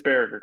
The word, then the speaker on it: Baragar: It's Baragar.